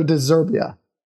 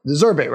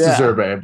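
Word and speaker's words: De Zerbi: What should we all, not just you, what should we all expect from the Potterless Brighton team now that they brought in Roberto De Zerbia? De Zerbe, right? – I think it's De Zerbe.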